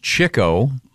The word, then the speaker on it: Chico: Chico, it's pronounced Chico, really.